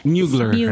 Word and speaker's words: Mugler: Mugler. – Mugler, right? – Yeah.